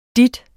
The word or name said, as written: dit